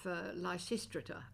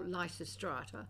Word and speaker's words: Lysistrata: Well, before that, there was a production at the Old Toad of Lysistrata, or Lysistrata, whichever pronunciation you prefer, with an amazing cast of people.